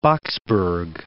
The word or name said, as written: Boksburg